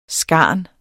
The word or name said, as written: skarn